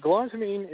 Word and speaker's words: galantamine: It's galantamine? – Yeah, galantamine.